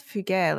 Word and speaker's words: fougère: What the hell's fougère? Let me just double check that. – How's it spelled? – F-O-U-G-E-R-E. Pronounced fougère. Fougère.